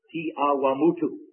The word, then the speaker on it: Te Awamutu: That sounds strange to you, but that's how you pronounce it, Te Awamutu, a Maori name.